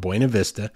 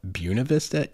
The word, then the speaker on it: Buena Vista: The locals there, believe it or not, at least a certain portion of them call it Buena Vista. And that's actually even in documentation from the early days of the town. It was almost taken on as a pronunciation. And that is just an absolute travesty. That is an affront to the Spanish language.